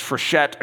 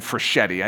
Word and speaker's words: Frechette: He has this pretty girlfriend named Billy, and I've heard her name pronounced 2 different ways, Frechette and Frechetti. I think it's Frechette.